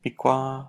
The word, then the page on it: pikwa: Lwaitel